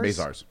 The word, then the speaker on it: Mazars: You actually tweeted, so with Trump's accounting for Mazars, I don't know how you say it, Mazars? – Mazars. – Oh.